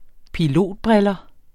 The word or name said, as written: pilotbriller